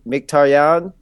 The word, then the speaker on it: Mkhitaryan: I think that the actual, you know, proper pronunciation is probably Mkhitaryan. – Yeah. – But I mean, like, if you're listening to like a broadcast on NBC Sports or whatever, like they're gonna, or if you're playing on FIFA, they're gonna call him Mkhitaryan.